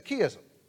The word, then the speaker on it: chiasm: And Jeff says, oh, David, that's a chiasm.